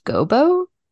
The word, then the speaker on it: gobo: When I was looking through your brochure this year, another thing that I had never seen before was, I think it's pronounced gobo. Is that how you say that? – Yeah.